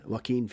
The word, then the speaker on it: Joaquin: Yeah, Joaquin Phoenix.